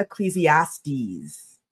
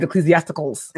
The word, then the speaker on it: Ecclesiastes: I know that in the Bible, a lot of the words, you see them, yes, they can be hard to pronounce, but if you say the word exactly as it's spelled out, it comes out right. – Comes out right. – So the idea that he looked at Ecclesiastes.